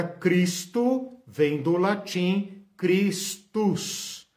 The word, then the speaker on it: Christus: Como se fala Cristo em latim? Christus, Christus, ok?